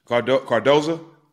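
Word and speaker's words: Cardoso: No one wants to be a part of that, but she wishes that, how do you say the girl's last name? – Cardoza.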